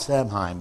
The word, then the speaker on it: Samhain: It's spelled Samhain, but it's actually pronounced Samhain.